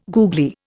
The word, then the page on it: googly: Indian